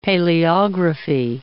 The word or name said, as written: paleography